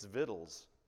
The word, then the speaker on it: victuals: Wherefore our elders and all the inhabitants of our country spake to us, saying, take, and I pronounced this word victuals last time, and I was told I'm wrong, so I researched it. It's victuals.